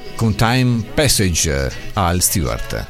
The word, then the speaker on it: Al Stewart: Io dissi all invece vedi subito, e invece una A, anche se non è una A proprio aperta, però insomma non si pronuncia certamente come all perché significa tutt'altra cosa.